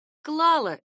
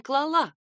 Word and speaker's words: клала: Она клала вещи на полку. Ударение в форме женского рода прошедшего времени падает именно на первый слог.